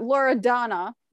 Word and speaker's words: Loredana: But Loredana. That's how you really say it. – Yes, that is how you say it in English without the accent.